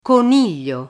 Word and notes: coniglio: Italian

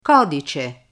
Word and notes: codice: Italian

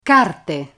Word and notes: carte: Italian

